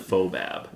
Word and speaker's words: FOBAB: I believe the official title is the Festival of Wood and Barrel-Aged Beers, but they just kind of ignore that W in there and just it's go silent. – FOBAB. – It's a silent W. – It's not a silent, it's just not part of the, it's an implied W.